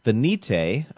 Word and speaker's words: Venite: I'm excited for my theological buzzword because depending on the context, it is pronounced two different ways. The first way is venite, which is the Latin for O come.